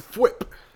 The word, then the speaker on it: Thwip: How do you pronounce this word? – It's Thwip.